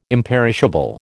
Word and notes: imperishable: American English

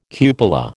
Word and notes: cupola: American English

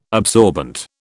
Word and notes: absorbent: British English

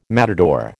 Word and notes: matador: American English

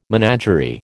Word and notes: menagerie: American English

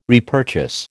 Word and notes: repurchase: American English

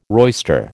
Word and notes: roister: American English

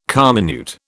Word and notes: comminute: British English

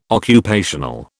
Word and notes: occupational: British English

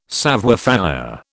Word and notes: savoir faire: British English

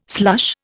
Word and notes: slush: Indian

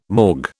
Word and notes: morgue: British English

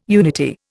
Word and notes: unity: Indian

